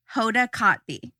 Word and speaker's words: Hoda Kotb: Hoda Kotb.